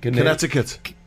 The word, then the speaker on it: Connecticut: Connecticut.